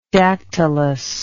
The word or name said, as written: dactylus